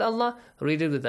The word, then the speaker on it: Allah: يَضُرَّ اللَّهَ, Allah is pronounced heavily. Allah, this way, not Allah.